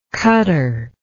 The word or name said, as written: cutter